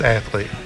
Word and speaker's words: athlete: The word athlete, is it two syllables or three syllables? – Two syllables.